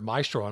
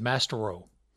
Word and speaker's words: maestro: Well, I had never seen the word maestro, and I'm probably 19 years old or something. And I said Mastero on the radio instead of maestro because it's spelled Mastero.